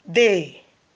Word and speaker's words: des: The examples of partitif article are do, dollar, dull, day. Do, dollar, dull, day. Now, do is spelled D-U. Dollar, D-E-L-A. Dull, D-E-L apostrophe. And day, which is spelled D-E-S.